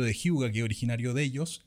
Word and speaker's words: hygge: Si buscan Hyuga, se escribe H-Y-G-G-E, si buscan eso en Google, van a ver el concepto.